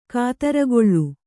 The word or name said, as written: ಕುದುರುಗೊಳ್